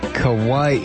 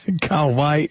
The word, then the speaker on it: Kauai: Mike, not that it matters, but it's pronounced Kauai with a short A in the K-A, like Kaka poo poo. Kauai.